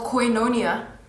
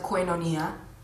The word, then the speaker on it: Koinonia: So we're going to be doing a sermon series called Koinonia. Some people like to pronounce it Koinonia, and it means fellowship in Greek.